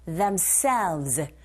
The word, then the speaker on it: themselves: People of rurals who need to supply themselves. 끝발음 하실 때 selves 하지 마시고 themselves. 에! 이 부분에 강세해 주셔서 v 발음만 입술 잡아주시면 돼요.